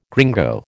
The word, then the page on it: gringo: American English